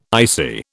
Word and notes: icy: British English

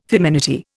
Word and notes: feminity: Indian

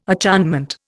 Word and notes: acharnement: Indian

